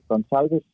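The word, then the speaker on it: Gonçalves: Øh, men, men det er altså bare sådan helt øh, udtalelsesmæssigt simpelt, at den der lille, øh, lille femtal under C'et i Gonzalves, som hedder en Sevilla, det gør, at, øh, at det altså skal udtales som et S.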